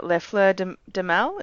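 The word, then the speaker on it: Les Fleurs du Mal: Uh, Lefleur de, de Mal, is that correct? – That's, yeah, that's great, yeah.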